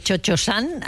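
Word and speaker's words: Cio-Cio-San: Sí, y esta ópera y este dúo entre Chocho-san y la Suzuki es absolutamente maravilloso. – Sí, pero cada vez que dices Chocho-san, a mí me da algo, ¿sabes? – Bueno.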